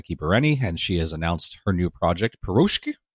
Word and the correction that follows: Piroshka: Piroshka? – Piroshka. – However it's pronounced.